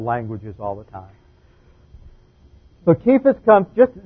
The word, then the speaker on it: Cephas: So the correct pronunciation of this is really Cephas.